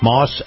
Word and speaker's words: Maus: is brought to you by our friends at Moss Acura. Moss is spelled M-A-U-S.